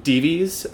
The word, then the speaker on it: Dives: Tradition has given him the name Dives, I think is how it's pronounced. It's spelled like the word dives, D-I-V-E-S. I've heard it pronounced Dives, so I'm going with that.